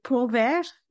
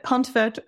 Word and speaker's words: Pontvert: It's very tricky because in French we would say Pontvert. Swallow most of the letters, but Pontvert is beautiful too because Then people know how to spell it.